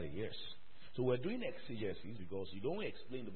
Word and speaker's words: exegesis: It's pronounced exegesis. Exegesis.